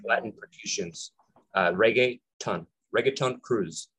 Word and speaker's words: reggaeton: Reggaeton. Think of reggae, right? Reggaeton. So reggae, the music is with T-O-N,